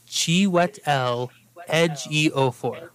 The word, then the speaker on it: Chiwetel Ejiofor: It is Chiwetel Ejiofor.